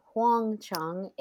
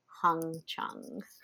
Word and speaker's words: Huang Chung: At the start of their career as a band, they were known as Huang Chung, H-U-A-N-G, which means yellow bell in Chinese. It wasn't until the early 80s that they changed it to Wang Chung, W-A-N-G, because too many people were mistakenly pronouncing it as Hung Chung.